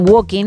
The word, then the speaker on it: Walking: Os diría que no pronuncian la L, al igual que ocurre en Walking.